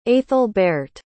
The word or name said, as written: aethelberht